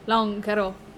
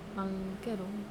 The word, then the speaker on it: lonkero: It literally means long drink. Long caro. That's how it's pronounced. Long caro.